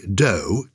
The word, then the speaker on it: dough: As a child, I could never work this out, why dough, D-O-U-G-H, is pronounced dough, whereas enough, the same ending, O-U-G-H, you're pronouncing the off.